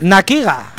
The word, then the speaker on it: Nakiga: Nakiga. – Cállate. – Nakiga. – Nakiga. – Has oído bien. – Como con acento en la I.